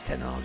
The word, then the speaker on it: Ten Hag: On now to the Spurs boss, Antonio Conte, warning Ten Hag about the big demands that the Premier League has. – Oh, you said his name properly? – Yeah, it's Ten Hag, isn't it? – In England, it's not so easy.